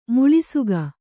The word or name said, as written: ಮುಳ್ಳುಸೀಗೆ